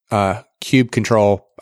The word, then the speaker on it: kubectl: And then for the main command that you're going to use to enter in all your commands, which I pronounce it kube control. I don't know if there's something else but it's K-U-B-E-T. No, can't say that wrong. K-U-B-E-C-T-L.